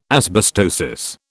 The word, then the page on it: asbestosis: British English